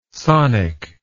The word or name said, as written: sonic